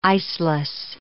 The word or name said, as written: iceless